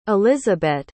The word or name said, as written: elisabette